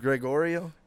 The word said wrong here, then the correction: Giorgio: No, it's not Gregorio. – Gregorio? – Giorgio.